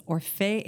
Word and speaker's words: Orfeu: The full pronunciation is Orfeu Eurydice, but we just call it Orfeu.